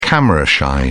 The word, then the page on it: camera shy: British English